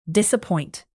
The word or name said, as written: disappoint